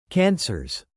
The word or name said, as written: cancers